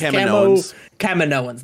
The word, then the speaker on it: Kaminoans: I think that the interesting moment at towards the end when you see the Kaminoans. How do you pronounce this? – Kaminoans.